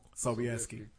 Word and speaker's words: Sobieski: Sobieski.